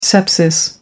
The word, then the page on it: sepsis: American English